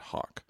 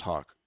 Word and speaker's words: hoc: I always said hawk. – Well, that's the dumb Italian that gets in the way. O's are O sounds. Yes. So I see foreign anything, and it's always pronounced with a little bit of Italian vowels.